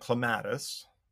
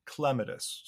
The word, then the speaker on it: clematis: Well, growing up, I called it clematis. But then since I learned that the correct botanical pronunciation is clematis, so the accent is on the first syllable.